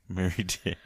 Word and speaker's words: Mary Dare: And it's spelled like Christmas. – Mary. Mary. – And then Dare, like the program to keep you off drugs. – Yep. – Mary Dare.